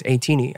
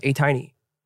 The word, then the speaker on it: ATEEZ: By the way, wait, I already talked about this before, but like, I called it 18-y because apparently it's 18-y. I thought it was A-tiny. – It's not A-tiny?